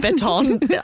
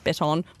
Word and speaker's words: baton: A baton. Not pronounced baton. My boss told me off that.